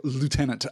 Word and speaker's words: lieutenant: The reason, by the way, that there's an alternate pronunciation there is that before it came into American English, the spelling had shifted. So it is spelled lieutenant. So pronouncing it lieutenant makes a lot of sense. But originally, when it came from the old French, the U was not a U, it was a V.